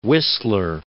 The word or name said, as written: Wissler